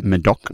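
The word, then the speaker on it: Médoc: Medoc.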